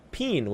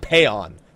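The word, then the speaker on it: Paean: The what else could a what bard change could they possibly do other than Wanderer's Peen, which people don't like the way I pronounce it, but that's what it is. It's not Payon.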